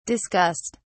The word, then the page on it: discussed: British English